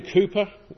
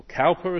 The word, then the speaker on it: Cowper: William Cowper, or Cowper as people mispronounce his name, was a hymn writer.